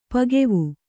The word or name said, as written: ಪಕ್ವ